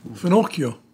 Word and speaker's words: finocchio: Finocchio. – Finocchio.